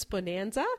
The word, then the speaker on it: Bohnanza: One's Bonanza, which is spelt B-O-H-N-A-N-Z-A or Z-A for you Americans.